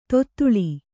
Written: ತೊದಳ್

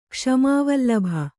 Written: ಕ್ಷಮಾವಲ್ಲಭ

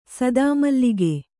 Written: ಸದಾಮಲ್ಲಿಗೆ

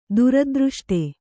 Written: ದುರದೃಷ್ಟ

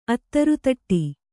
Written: ಅದಿರುದುಟಿ

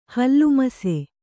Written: ಹಲ್ಲುಮಸೆ

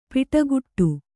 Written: ಪಡಿಕಟ್ಟು

